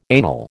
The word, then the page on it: anal: American English